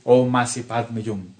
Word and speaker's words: Om Mani Padme Hum: El mantra Om Mani Padme Hum se pronuncia esotéricamente Om Masi Padme Hum, alargando el sonido de cada letra y en forma silabiada.